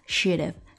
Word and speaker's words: shoulda: 之前在学习黄老板 Perfect 那首歌曲的时候，有一句歌词当中有 should have 它的缩读 should have shoulda shoulda。